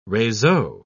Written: reseau

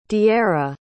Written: diera

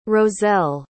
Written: rozelle

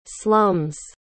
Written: slum's

